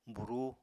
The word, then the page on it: burúu: Apatani